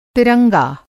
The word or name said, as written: तिरंगा